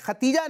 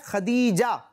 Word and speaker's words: خدیجہ: ماشاء اللہ حسن بھائی کا جواب بھی درست ہے, یہ خدیجہ نہیں بلکہ اس کا تلفظ کیا ہے؟ خدیجہ, دال کے ساتھ خدیجہ. کیا ہے؟